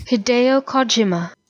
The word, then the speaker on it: Hideo Kojima: Hideo Kojima. – Kojima. – Well, the part I thought I knew, apparently I didn't. And the part I wasn't sure about. – So Hideo Kojima.